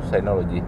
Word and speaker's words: Synology: Se escribe Synology, pero en inglés pronunciado Synology.